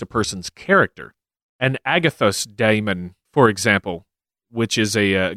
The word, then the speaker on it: daimon: It is literally a diphthong in the word daimon, not daimon.